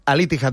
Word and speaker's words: Al-Ittihad: Pronúncialo tú. – Yo creo que es Al-Ittihad. – Pues eso. – Al-Ittihad, me dice Marco desde la redacción, que es el que más sabe de fútbol, que bien.